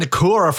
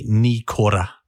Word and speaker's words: Nikora: He's got a Māori surname, which is not Nakora. It's actually Nikora. So ni, like your knee, core, like an apple core, and then roll your R on the ra.